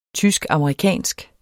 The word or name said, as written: tysk-amerikansk